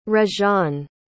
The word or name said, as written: reganne